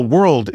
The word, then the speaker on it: world: But actually, for example, world is technically, it's two parts, right? Because it's wor and old. It's not just wort, wort, like that, right?